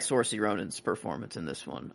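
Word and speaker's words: Saoirse: I always pronounce her first name wrong. Swarcy. And I'm afraid to say it. Sort. Huh? – Swarcy. – Swarcy. So like a W in there.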